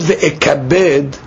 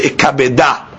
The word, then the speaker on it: v'ikabeda: Now the word v'ikabed, we're reading it v'ikabed, but it's written without a heh. The word is actually v'ikabeda. That's the word, v'ikabeda.